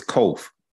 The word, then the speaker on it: kof: It's not kaf, it's kof.